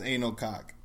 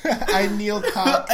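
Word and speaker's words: Anil Koç: There's no way that was pronounced Anal Cock. – A-N-I-L space K-O-C. How do you pronounce that? – I would say Anil. – And how would you pronounce his last name? – Cook. – So say it two together.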